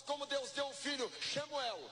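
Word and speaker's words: Shamuel (שמואל): Na pronúncia hebraica, Samuel, nós podemos entender, como os judeus mesmo entendem o nome Samuel, que é Shamuel, que quer dizer Shem, é nome, nome dado por Deus.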